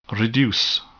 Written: reduce

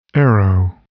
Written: arrow

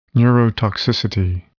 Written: neurotoxicity

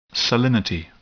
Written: salinity

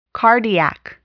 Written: cardiac

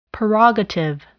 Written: prerogative

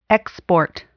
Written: export

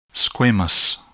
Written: squamous

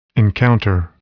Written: encounter

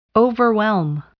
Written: overwhelm

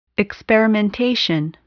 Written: experimentation

